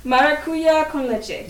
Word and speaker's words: Maracuya con Leche: Maracuya con leche.